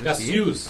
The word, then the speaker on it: Cassius: Cassius. – Sì. – Cassius, giusto, pronuncia esatta.